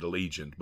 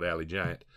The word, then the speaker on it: Allegiant: Yeah, some of y'all pronounce it Allegiant, but we call it Alley Giant.